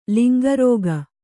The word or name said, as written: ಲಿಂಗರೋಗ